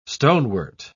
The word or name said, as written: stonewort